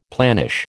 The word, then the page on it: planish: American English